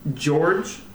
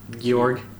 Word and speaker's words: Georg: George, I'm assuming that's how you pronounce that. G-E-O-R-G. – Jorg.